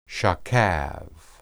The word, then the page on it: shakab: Hebrew